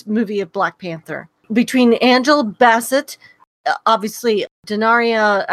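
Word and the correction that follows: Danai Gurira: I always pronounce her name wrong, and I should get it right because I call her Michonne, but yeah. I do it, you know. – It's Denai Guerrera.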